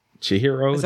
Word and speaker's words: Chihiro: Chihiro? That sounds right. That sounds right. – That's how you say it.